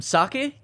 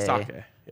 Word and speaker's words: sake: Actually, just one person in the YouTube comments for calling it sake instead of sake. – Oh, sake. – Really? – Sake. Yes.